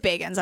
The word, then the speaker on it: Bagans: Zach Z-A-K, by the way. – Z-A-K. And it is Bagans. I was wrong. Because I wrote it out phonetically in my notes.